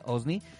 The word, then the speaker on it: OSNI: No lo dije mal, así se dice, OSNI.